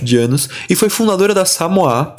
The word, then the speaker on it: Samois: S-A-M-O-I-S. Samois, que se lê em português, mas que se você for ler pelo original da palavra, que é essa palavra em francês, pronuncia-se Samoa. Então eu vou falar tanto Samois, que é o jeito que a gente conhece aqui no Brasil, como Samoa, beleza?